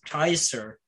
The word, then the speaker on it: Caesar: the word Caesar itself, because it's in reality, that's not at all how you say it, because it's Kaiser, because the C isn't really a C, like it's a K, pronounced the K.